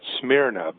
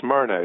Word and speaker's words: Smyrna: A lot of people pronounce that Smyrna, but it's Smyrna is the correct pronunciation.